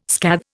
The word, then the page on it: scathe: Indian